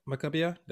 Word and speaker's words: Maccabiah: and he actually went to the Maccabiah.